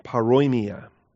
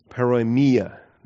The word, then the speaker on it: paroimia: Now, in Greek, two terms we want to understand in Greek, starting with paroimia, P-A-R-O-I-M-I-A, paroimia, or the accent on the ia, paroimia, there we go, that's better.